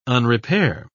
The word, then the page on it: unrepair: British English